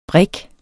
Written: brig

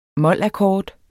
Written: molakkord

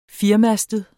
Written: firemastet

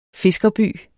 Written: fiskerby

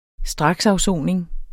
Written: straksafsoning

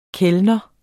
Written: KELNERNES

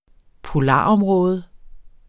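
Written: POLAROMRÅDER

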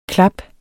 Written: klaps

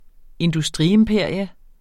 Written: industriimperium

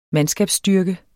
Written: mandskabsstyrke

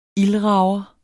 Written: ildrager

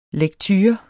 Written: lekture

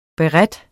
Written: BERETERNES

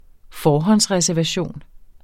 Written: forhåndsreservation

